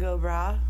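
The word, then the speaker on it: bragh: It's spelled B-R-A-U-G-H, but it's pronounced go bra. – Like, okay, so like bra, like somebody's like, hey, bra.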